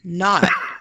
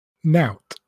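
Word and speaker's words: naught: Well, it's ought not, right? Is that not how you say them? – You'd think so, wouldn't you? Yeah. Right. The first one is pronounced out. – Okay. – And the other one is pronounced nout. – Okay. So out and nout instead of ought and not.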